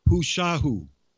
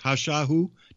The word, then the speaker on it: Hushahu: A healer, a medicine keeper of her tribe, she's called Hushahu. It's like Hush and then A-H-U afterwards, Hushahu, depending on how you pronounce it, where you're from.